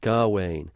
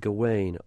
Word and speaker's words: Gawain: There is one stanza in the poem where the rhythm of the line seems to suggest very heavily that it's pronounced Gawain. On the other hand, because of the alliteration, there are other and many of the moments in the poem where it seems to be saying Gawain with a heavy G.